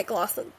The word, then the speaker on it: McLaughlin: Oh my gosh, I totally butchered that. – MccLaughlin. – Didn't I? MccLaughlin, thank you.